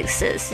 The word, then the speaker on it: exist: So your wings already exist 是一个譬喻。 Exist，注意一下它的发音，这个 ig 哥哥很轻的 exist。